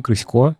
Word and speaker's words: Крысько: Вот, а сегодня у нас в гостях Дим Крысько, HR-директор РБК Мани.